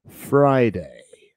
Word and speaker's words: Friday: Ok, y se dice así, Fry, Friday.